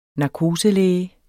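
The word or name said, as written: NARKOSELÆGE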